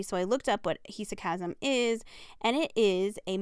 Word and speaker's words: hesychasm: The term meditation in English, it may also point to practices from Islamic Sufism or Jewish Kabbalah and Christian hesychasm, I think is what it's how it's pronounced. I looked it up and now I can't remember, but it's hesychasm.